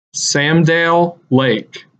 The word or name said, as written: Sam Dale Lake